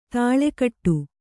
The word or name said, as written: ದಳೆಕಟ್ಟು